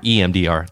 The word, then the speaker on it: EMDR: I did EDMR, which I can't pronounce that anymore. It's like an eye movement, EMDR. There we go.